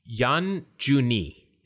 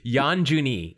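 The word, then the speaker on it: Yan Juni (四十二): It is because the uh, number 42, he told me, is first, it's pronounced Yan Juni. And it, please pardon my, my Japanese, a little rusty. Yan Juni is how you say 42.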